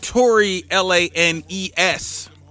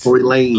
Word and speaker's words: Tory Lanez: Tory Lanez. – Oh, Tory Lanez. – Tory Lanez.